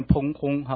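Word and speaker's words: 砰訇: 好，这两个字念烹轰哈，烹轰烹，发音跟烹饪的烹一样哈，轰发音跟轰炸的轰一样哈，烹轰翻腾哈，就是翻动的样子哈，那个声音哈，山水因海涨而颤抖，我们也不害怕。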